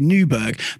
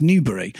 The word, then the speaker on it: Newbery: He's just got the thing about your surname is that it looks like it should be Newberg, but it's pronounced Newbery.